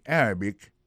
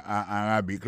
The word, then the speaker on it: Arabic: Mohammed speak Arabic and English. Arabic là, c'est comme ça, on dit arabe en anglais. Arabic. A-R-A-B-I-C. Golem d'ABC, prononcez moi arabique là.